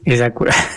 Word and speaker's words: Acura: Así como Lexus es la marca de lujo de Toyota, y así como Acura o Acura, así porque el señor español se enoja de que, está mal pronunciado. Entonces, Acura.